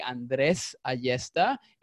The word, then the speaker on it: Andres Ayesta: Um, I guess like the, the right uh, pronunciation for my name would be Andres Ayesta. If you want to actually kind of like you know try to roll the R's in there,